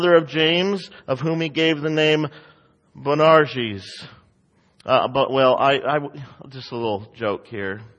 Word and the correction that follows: Boanerges: I worked really hard on trying to get the proper pronunciations, and I just messed it up. So let me try it again. I think it's Boanerges.